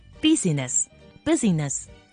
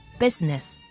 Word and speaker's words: business: 开头 busi 同我哋成日讲好忙碌嘅英文 busy 系好相似嘅，而最后尾 ness 代表佢系一个名词，所以好多人就会将呢啲字连埋一齐读，将呢个字读成 business 或者系 business。但系除咗开头同 busy 好似嘅 bus。同埋后尾象征住系名词嘅 ness 之外，其实连接住头同埋尾中间嘅 i，我哋系唔需要发声噶，所以呢个字应该读成 business。